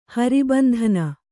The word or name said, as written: ಹರಿಬಂಧನ